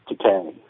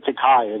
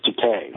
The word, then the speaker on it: Takei: It's Takei. You said Takei. It is Takei.